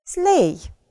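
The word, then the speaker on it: sleigh: Вот это тот пример, когда, как я вам говорила, да, нагромождение просто всяких букв разных, а читается-то всего «slay». Четыре звука. В транскрипции это слово будет выглядеть вот так. Все. Все. То есть у нас в серединке с вами буквосочетание, а все остальное не читается «slay».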